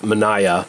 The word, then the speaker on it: Manaja: This movie is also known as Manaya, although it's sort of it's spelt Manaja. You might have seen that title floating around, but I guess it's pronounced Manaya, and it means hatchet.